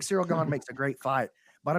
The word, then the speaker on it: Cyril Gane: Now, Cyril Gane, it's so you say I don't know who he is, but y'all don't even know how to pronounce his name. It's Cyril Gane.